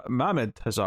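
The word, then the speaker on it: Mahmud Asrar: All right, Batman versus Robin, issue 3, Mark Waid, rating with Mohamed Hazar and, Mohamed Hazar. I was adding the extra syllable.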